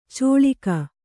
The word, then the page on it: ಚೋಳಿಕ: cōḷika